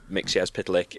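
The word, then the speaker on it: Mxyzptlk: which is the reason I know how to pronounce Mixyazpitalik is that in John Byrne's Superman run, he has him spell it out phonetically so that you know how to say it.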